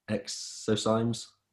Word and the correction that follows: exosomes: Exosomes, yeah.